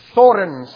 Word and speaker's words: Thorin: Thorin, son of Thrain, son of Thror, king under the mountain, right? You have to say it like that. You have to trill the R's.